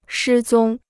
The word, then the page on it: 失踪: shī zōng